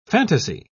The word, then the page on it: phantasy: British English